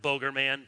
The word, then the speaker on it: Bogerman: I prefer to pronounce it Bogerman, but you can pronounce it as you like.